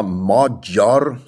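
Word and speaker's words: Magyar: Also Peter Modjor, so wird der Name richtig ausgesprochen, und das heißt eigentlich übersetzt der Ungar.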